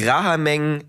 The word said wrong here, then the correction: Rahajeng Semeng: nee, Rahajeng Semeng.